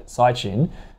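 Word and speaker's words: Caixin: Again, we spoke about it before, but that services PMI data, the Sychin figure. – How they pronounce it. – Apparently. I'm being coached.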